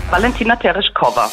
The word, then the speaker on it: Valentina Tereshkova: Valentina Tereshkova.